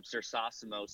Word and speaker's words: Cersosimo: Um, I'm both on Instagram and Twitter at Jacob Cersosimo, C E R S O S I M O, kind of a tongue twister. Um, you probably won't um, say it right unless you hear me say it right, and you probably won't spell it right unless I spell it for you.